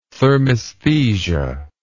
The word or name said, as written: thermaesthesia